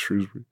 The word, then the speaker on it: Shrewsbury: But, even people from Shrewsbury will tell you it's Shrewsbury. – I think I've just always listened to Soccer Saturday, and that's how they say it. Shrewsbury.